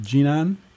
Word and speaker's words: Jinan: Is it pronounced Jinan? – In Jinan, yes.